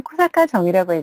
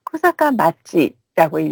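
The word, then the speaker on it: 코사카 마치라고 (小坂町): 조금 전에 편지에는 코사카 정이라고 해주셨는데요. 실제로 이 정은 코사카 마치라고 읽어요.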